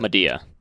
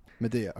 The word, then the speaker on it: Madea: Madea. – Madea, okay.